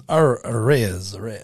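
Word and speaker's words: Arraez: It's Arez. You gonna do that? Arez.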